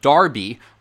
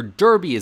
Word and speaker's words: Derby: Now nearly every country, every state, has a race for three-year-old horses with Derby, or Derby as Americans pronounce it, in its name.